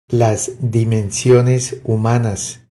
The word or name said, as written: Las dimensiones humanas